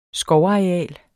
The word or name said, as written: skovareal